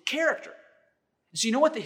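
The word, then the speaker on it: character (χαρακτήρ): The Greek word there for exact representation is the Greek word that is pronounced character.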